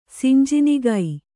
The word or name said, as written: ಸಿಂಜಿನಿಗೈ